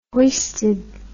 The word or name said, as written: hoisted